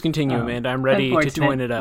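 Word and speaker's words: Táin: I think the toine is a Irish folklore / mythology book that I had to read in college, but it's spelled T-A-I-N, but it's pronounced the toine.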